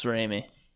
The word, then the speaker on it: Raimi: Sam, it's Raimi, right? Is how you say the last name? – Yes, Raimi.